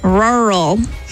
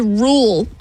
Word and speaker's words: rural: I'm saying it right because it sounds like your car trying to start when it's really cold. It's like rural. – Logan says rule. I'm like, you're missing an R.